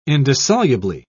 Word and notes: indissolubly: British English

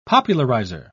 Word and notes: popularizer: British English

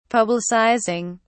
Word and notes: publicizing: British English

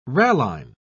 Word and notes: ralline: British English

